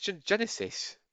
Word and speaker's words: Genisys: Wait, say Genisys. – But that's how it's spelt. – It's Genesis. – That's not how you spell Genesis. – Fair enough. – It's spelt Genisys. – I like how you were so like... – Like Fan Four Stick. It's just... – Fan Four Stick. – If you spell it dumb, I'll pronounce it dumb.